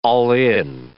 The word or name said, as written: all-in